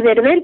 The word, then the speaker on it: Berbel: Y Berbel,